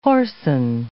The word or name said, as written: whoreson